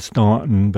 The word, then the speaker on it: Staunton: It's spelled Staunton, but it's pronounced Staunton.